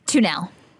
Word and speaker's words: Tuneal: Kathy Tunnell. – And what's your last name, Kathy, sorry? – Tunnell. – Spell that for me. – T-U-N-E-A-L.